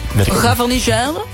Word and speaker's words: Gavarnie-Gèdre: Van Pau naar Gavarnie-Gèdre. Zeg ik het goed, Jan? Gavarnie-Gèdre? – Ja, het is tussen Pau. – Pau. – Ja, en de andere... – Gavarnie-Gèdre.